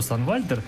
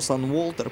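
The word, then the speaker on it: Sunwalter: Многие произносят его Санволтер, причем, когда мы были в европейском туре, многие тоже так произносили, читают по-английски потому что так принято. Вот. Ну, это еще один повод для обсуждения. Я могу сказать, что правильно читается Сан Вальтер, потому что я сказал уже, что Сан английская, Вальтер немецкая, значит немецкий кусок надо читать по-немецки.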